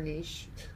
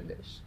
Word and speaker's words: niche: Her niche. Her niche. – I say it both ways.